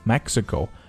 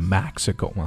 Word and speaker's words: Mexico: Mexico 注意它的口型呢开口都不是很大、啊、 Mexico 不是 Mexico、